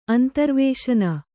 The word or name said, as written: ಅಂತರ್ವೇಶನ